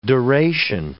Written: duration

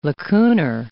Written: lacunar

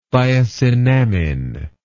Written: thiosinamine

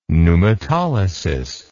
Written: pneumatolysis

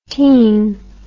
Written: tene

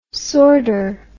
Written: sordor

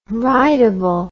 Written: rightable

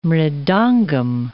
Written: mridangam

